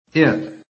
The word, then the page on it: IT: American English